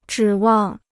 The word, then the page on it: 指望: zhǐ wàng